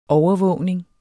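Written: overvågning

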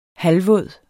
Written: halvvåd

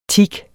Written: teak

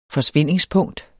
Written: forsvindingspunkt

